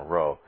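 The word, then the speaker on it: rho: it's a key and a row, not a chi. Don't let fraternities tell you how to pronounce Greek letters. It is a key, key row.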